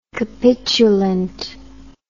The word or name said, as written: capitulant